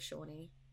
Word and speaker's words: Shawnee: Or Shawnee, because her name's like Shawnee. – So yeah, I've always pronounced it as Shawnee.